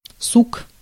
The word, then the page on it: branch: /ˈbɹæntʃ/; /ˈbɹɑːntʃ/